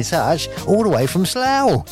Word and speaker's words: Slough: She says, I'm in Slough now. It rhymes, doesn't it, Rebecca?